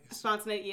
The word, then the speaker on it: spontaneity: Am I saying that right? – Spontaneity, yeah. You're saying spontaneity.